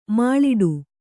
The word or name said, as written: ಮುಳ್ಳಿಡಿ